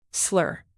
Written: slur

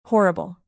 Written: horrible

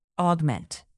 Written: augment

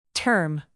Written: term

